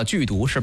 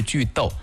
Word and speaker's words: 句读: 很多人说太简单了，句读是吧？其实不对，应该是句逗，这个逗通逗号的逗，句逗简单来讲就是断句的意思。为什么要说这两个字呢？因为我们推荐的 APP 跟这两个字有关。先来说这两个字是什么意思，句逗，逗是读书的读，句逗只不过在这个地方它的发音是逗。